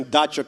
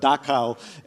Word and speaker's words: dacha: Yeah, and thank you for pronouncing dacha correctly. Many people I know call it Dachau, and ooh. I'm like, that's a whole different thing. That's a different kind of camp.